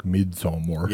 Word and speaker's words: Midsommar: So now, Midsommar is, I mean, it's actually like Midsommar. – Yeah. Right. You got to say it right. You got to say it like you're in IKEA.